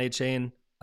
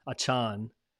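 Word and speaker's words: Achane: Somebody said earlier, I was listening to a podcast, I said Achan. I don't know how to pronounce He corrected it. – He must have, after everybody was like saying his name. I'm sure he probably dropped it. He's like, that's Achain is not my name, it's Achan.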